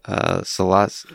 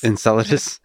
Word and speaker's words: Enceladus: Enceladus. – Enceladus.